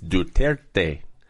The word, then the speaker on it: Duterte: I said, how do you pronounce his name? He says, Duterte.